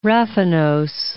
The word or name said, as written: raffinose